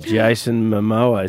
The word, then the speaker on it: Jason Momoa: Aquaman, Jason Momoa. Is that how you pronounce it? – Yep.